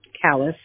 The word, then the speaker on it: Callis: Callis, C-A-L-L-I-S.